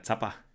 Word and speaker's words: Zapper: Also, Entschuldigung, ich habe gerade mit S ausgesprochen, das war falsch. – Alles also gut. der EM-Schablone e halt. Der Zapper.